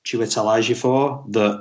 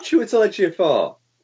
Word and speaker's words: Chiwetel Ejiofor: you forget that it's Chiwetel Ejiofor did I say that wrong? I probably did. – No, that's good.